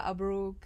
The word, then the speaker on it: Abrouk: Well, the best way is on Instagram, Sandra Abrouk.